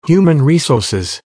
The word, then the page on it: human resouces: American English